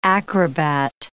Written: acrobat

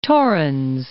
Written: Torrens, Lake

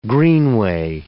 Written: greenway

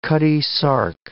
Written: Cutty Sark

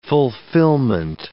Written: fulfillment

